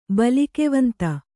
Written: ಬಲಿಕೆವಂತ

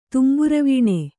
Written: ತೌಂಬುರವೀಣೆ

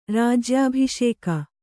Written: ರಾಜ್ಯಾಭಿಷೇಕ

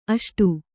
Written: ಅಷ್ಟು

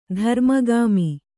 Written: ಧರ್ಮಗಾಮಿ

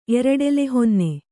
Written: ಎರಡೆಲೆಹೊನ್ನೆ

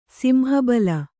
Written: ಸಿಂಹಬಲ

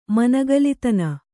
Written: ಮನಗಲಿತನ

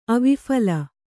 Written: ಅವಿಫಲ

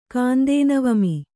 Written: ಕಾಂದೇನವಮಿ

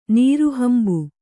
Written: ನೀರುಹಂಬು

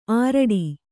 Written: ಆರಡಿ

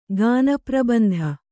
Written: ಗಾನಪ್ರಬಂಧ

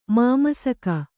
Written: ಮಾಮಸಕ

